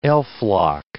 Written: elflock

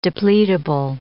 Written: depletable

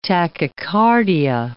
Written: tachycardia

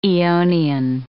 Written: aeonian

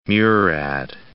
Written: Murad

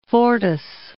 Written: Fortas

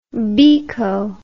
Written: biko

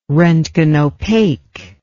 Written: roentgenopaque

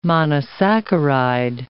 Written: monosaccharide

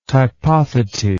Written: typothetae

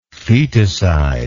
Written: feticidal

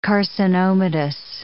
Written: carcinomatous

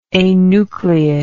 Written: anucleate

